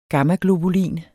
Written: gammaglobulin

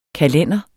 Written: kalender

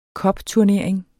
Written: cupturnering

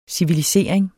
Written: civilisering

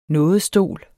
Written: nådestol

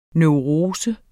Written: neurose

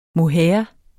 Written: mohair

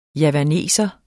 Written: javaner

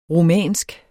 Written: rumænsk

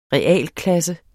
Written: realklasse

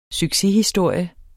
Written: succeshistorie

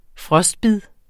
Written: frostbid

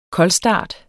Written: koldstart

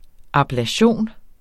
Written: ABLATION

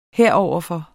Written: heroverfor

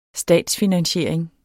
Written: statsfinansiering

